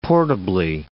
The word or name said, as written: portably